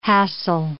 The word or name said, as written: hassle